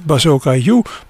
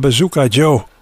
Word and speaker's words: Bazooka Joe: Maar in het Engels spreek je dat dus precies tegenovergesteld uit. Niet Bazooka U, maar Bazooka Joe.